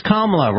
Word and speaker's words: Kamala: I said it wrong. Can't believe I did that. Kamala Harris. It's Kamala, rhymes with Mamala.